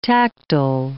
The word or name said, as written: tactile